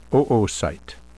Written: oocyte

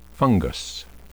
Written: fungus

